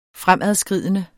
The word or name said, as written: fremadskridende